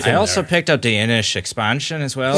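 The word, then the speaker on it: Inis: Apparently they pronounce it Innish, which is the Celtic way of saying island. – Innish. – You wouldn't think so because it doesn't have an H on the end, but that's what I've come to hear.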